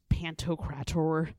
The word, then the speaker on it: Pantocrator: Well, okay, so it's sort of like, you know how there is like Christ Pantocrator? – I always Did you say that right? – I always have to say it like that. Yeah, look it up. That's exactly how you say it.